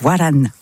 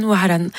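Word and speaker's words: Waran (وهران): Khaled, on l'écoute avec Waran. Waran, je prononce mal. Vas-y. – Tu es vraiment très proche. Waran, Waran, qui veut dire Oran.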